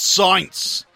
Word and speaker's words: Sainz: Carlos Sainz. – Is that how you pronounce his name? – Sainz. S-A-I-N-Z.